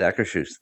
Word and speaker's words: Akershus: And I already made a breakfast reservation for Akershus. Is that what it's called? – Yeah, you did. – Oh, good. I pronounced it right. – Yes, really? You did. Akershus. That's it.